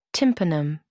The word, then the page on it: tympanum: American English